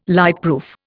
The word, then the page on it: lightproof: Indian